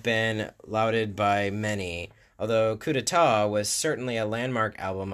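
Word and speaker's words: coup d'état: with Capitol Records releasing it in 82 as Coupe d'Etat. Coup d'etat, sorry.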